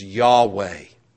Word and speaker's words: Yahweh: and most likely the correct pronunciation of his personal name is Yahweh, the personal God.